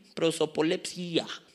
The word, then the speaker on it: prosopolepsia: Prosopolepsia, se escribe con, pues como se Prosopolepsia, con acento en la I. Prosopolepsia, con S las dos.